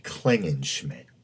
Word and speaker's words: Klingenschmidt: Well, anyway, I pronounced his name incorrectly as Klingenschmidt, but I guess it's actually Klingenschmidt.